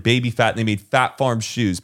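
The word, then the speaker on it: Phat Farm: Fat Farm. It's Fat Farm. – It's Fat Farm? – P-H-A-T. Fat Farm.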